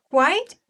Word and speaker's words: quite: มันสลับกันนิดเดียวคุณมาเรียมีคำว่า quiet แล้วมันทอยหาทางไม่ถูกแล้วงงเลย yeah, I said that it's quite important to keep a promise not quite important but quiet yeah pronunciation is not the same yeah quite is the adverb it means completely actually to a great degree yeah For example, we'll say elephants are quite large animals.